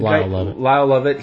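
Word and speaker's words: Lyle Lovett: And if you put him up against the old school country people, and I guess Kyle Love, am I saying that right? – Lyle Lovett.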